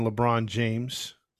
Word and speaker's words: LeBron James: But we're going to talk about Akron, Ohio, and that is the hometown of one LeBron James.